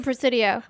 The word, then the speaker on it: Presidio: I believe that's how it's pronounced. Yeah, uh, it's the Presidio.